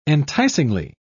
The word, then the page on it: enticingly: British English